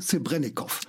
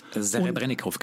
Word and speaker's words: Serebrennikov: Dann haben wir jetzt Platz 6, nämlich Leto von Kyrill Serebrennikov. – Serebrennikov, genau. – Ja, richtig. – Entschuldigung, dass ich ihn falsch ausgesprochen habe,